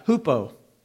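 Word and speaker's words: hupo (ὑπό): hupo. It's U-P-O, but it's a rough breathing mark, so we pronounce it H. H-U-P-O, hupo meno.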